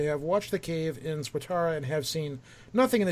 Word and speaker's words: Swatara: Swatara.